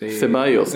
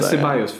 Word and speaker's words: Ceballos: Kibalas, Isco och Toni Kroos. – Ceballos där. – Ceballos, ja, förlåt.